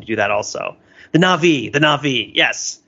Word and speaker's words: Na'vi: Should I be putting the emphasis on the first or second syllable? I feel like this is one of those things where it's like, are you a human or are you a native? – First or second syllable. I would say that are they Na'vi or are they the Na'vi? – Let's call them the Na'vi. – Na'vi. All right.